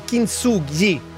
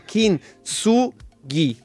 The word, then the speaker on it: Kintsugi: La pronuncia è Kintsugi. No, Kintsugi. Kintsugi.